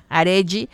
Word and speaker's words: Arelly: En Instagram, Arely, es con doble L-Y mi nombre. Se escribe A-R-E-L-L-Y, Arely. Arely Alcocer, locutora holística.